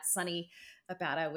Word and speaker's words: Sonni Abatta: And then on Instagram at Sonni Abatta, which is spelled S O N N I A B A T T A.